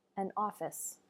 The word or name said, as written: an office